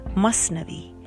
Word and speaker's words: Masnavi: Mathnavi, pronounced Masnavi, is Rumi's most famous book.